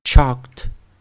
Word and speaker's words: chalked: 来，我们来看第一个生词啊，我们用加 ed 的时候，我们 onset 选择是 ch，ch，ch，chalk，chalked，chalked，注意要念 t，chalked。 chalked, chalked。